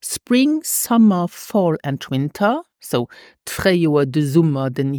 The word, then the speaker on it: Winter: Now, the Winter starts with the letter W, and this is pronounced like a V in English, like in view. So we say Winter, the Winter.